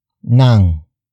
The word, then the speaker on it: ng: Nang. Nang. Nang is spelled as ng.